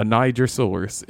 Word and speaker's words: Nigersaurus: I don't know who named it, but it's a Nigersaurus. It's actually pronounced the offensive way.